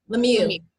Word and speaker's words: Lemieux: Is that how you say your last name? Lemieux. – Yes. It's French. It's like Creole, right? – It's French, yeah.